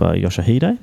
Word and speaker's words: Yoshihide: Yeah, moving on to the 200, you've got Hartman doubling up there with Yoshihide.